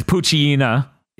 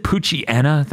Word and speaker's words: Poochyena: Pikachu, Meowth, Totodile, Swellow, Grovyle, Corphish, Torkoal, Corsola, Combusken, Beautifly, Skitty, Bulbasaur, Fortress, Lumbre, Mudkip, Metagross, Blaziken, Blastoise, Surskit, Masquerain, Espeon, Salamence, Poochyena. Don't they pronounce it Poochyena? That drives me insane. – Yeah. – Anyways. – Yeah, they do. – Because it's Hyena.